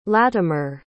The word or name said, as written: latimer